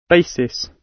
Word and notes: basis: British English

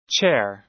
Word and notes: chair: American English

